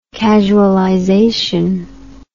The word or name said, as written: casualization